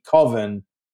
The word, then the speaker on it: Coven: About the independent, very independent filmmaker from Wisconsin, Mark Borchardt, and his struggles to finish his movie Coven, which turns out to be an acceptable alternate pronunciation. I have since found out.